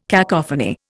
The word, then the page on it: cacophony: Indian